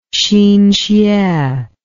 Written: hsin hsueh

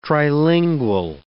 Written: trilingual

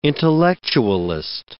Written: intellectualist

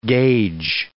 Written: gage

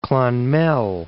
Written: Clonmel

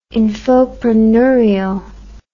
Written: infopreneurial

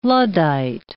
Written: Luddite

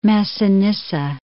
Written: Massinissa

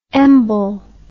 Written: embol

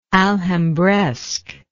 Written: alhambresque

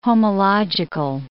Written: homological

